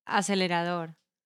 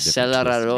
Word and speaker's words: acelerador: Acelerador. – Acelerador? – Yes. – Acelerador. – Acelerador? – I mean, it's very similar, but it's kind of acelerador.